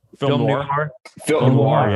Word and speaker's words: film noir: Film noir.